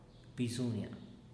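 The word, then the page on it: bizunia: Teotitlán del Valle Zapotec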